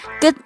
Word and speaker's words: good: Good morning。在读这句简单的问候语时，大家要特别注意 “good” 的发音。两个 o 发音的时候，先将嘴型做成类似饿的形状，就是肚子饿的饿哦。